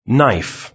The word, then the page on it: knife: British English